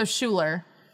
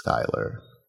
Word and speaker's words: Schuyler: His Skylar is spelled S-C-H-U-Y-L-E-R. – So Schuler. – But with a Y. It's Skylar.